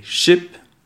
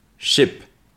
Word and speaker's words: ship: Et ship, qui est un bateau. Donc je répète les deux mots. Sheep, ship.